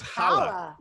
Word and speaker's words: challah: Wait, you pronounced the C? I thought it was challah.